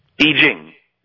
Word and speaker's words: I Ching: It seems to me that we have lost, and let's just look at the Chinese I Ching. Did I say that right? – I Ching. – Not bad.